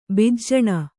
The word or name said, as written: ಭೂಜಾನಿ